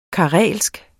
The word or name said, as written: karelsk